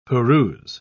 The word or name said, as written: peruse